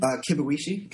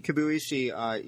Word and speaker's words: Kibuishi: How do you say his name? – Kibuishi. – Kibuishi.